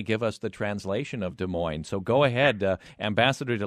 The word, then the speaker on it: Des Moines: And I will tell you, the only thing that we don't understand is the way you pronounce Des Moines, the Des Moines. And in French, we say Des Moines, of course.